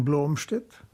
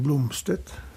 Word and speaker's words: Blomstedt: Es kommt darauf an, wo ich mich befinde. In Deutschland allgemein Blomstedt, in Skandinavien Blomstedt und in Amerika Blomstedt.